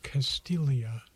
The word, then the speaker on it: Castiglia: He was preceded by Slumlord Billionaire, kind of the same financial theme, this time by Albert Castiglia, spelled C-A-S-T-I-G-L-I-A. The G is silent, but the guitar isn't.